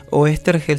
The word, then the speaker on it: Oesterheld: Oestergel se escribe Oesterheld.